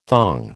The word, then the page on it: thong: American English